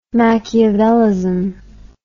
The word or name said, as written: machiavellism